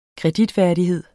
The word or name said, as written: kreditværdighed